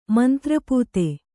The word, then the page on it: ಮಂತ್ರಪೂತೆ: mantra pūte